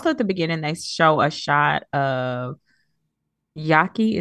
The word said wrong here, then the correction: Yaka: Is that how you say her name? – Yaka.